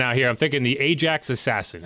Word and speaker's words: Ajax: How do you pronounce the – is it Ajax? – So, Toronto, Ontario. – Okay. – Ajax is like a suburb of Toronto.